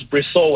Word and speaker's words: Bristol: It's Bristol without the T. Not Bristol.